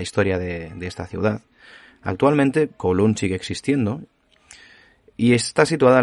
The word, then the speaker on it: Kowloon: Bueno, como habréis podido leer en el título del episodio, hoy venimos a hablar de, de Kowloon o Kowloon, pronunciado en cantonés.